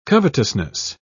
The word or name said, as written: covetousness